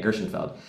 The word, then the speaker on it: Gershenfeld: Gershon or Gershom? I want to make sure I say his name right. Gershom. Oh, with an N. Okay. Gershenfeld.